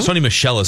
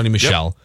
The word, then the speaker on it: Sony Michel: Sony Michel is how you pronounce it, right? Mm-hmm. Sony Michel. – Yep.